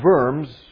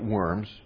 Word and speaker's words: Worms: And Worms, you might look at it and pronounce it Worms, was a city in Germany. It was a church council called to meet in the city of Worms.